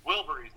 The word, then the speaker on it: Wilburys: Oh, we do the, like, Traveling Willoughbys or something like that. I say that right? – Wilburys or something? – Wilburys, that's it.